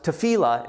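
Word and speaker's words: tefillah (תפילה): This right here on the board is the Hebrew word, if you haven't seen it before, and it's pronounced tefillah, and it means prayer.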